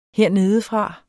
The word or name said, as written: hernedefra